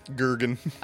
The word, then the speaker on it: Jurgen: He pronounces it Jurgen. – Yeah, it's a hard J.